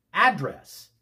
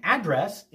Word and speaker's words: address: Address with the accent on the second syllable is a verb meaning to speak to someone directly. I want to address my remarks to everyone on the council today.